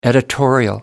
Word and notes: editorial: American English

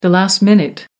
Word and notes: last-minute: American English

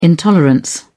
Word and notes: intolerance: British English

